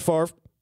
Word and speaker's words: Favre: Brett Favre. – How's it supposed to be pronounced? – No, I'm sorry. It's pronounced differently than how it's spelled. Like, it's F-A-V-R-E, so you probably like Favre.